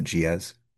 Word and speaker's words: Gilles: Two Regimes of Madness is, and Deleuze's first name is Giez, Gilles, but you pronounce it Giez.